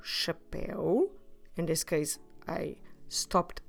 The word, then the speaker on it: chapéu: The word for hat is chapéu. Chapéu. Como se escreve chapéu? C H A P E com acento U.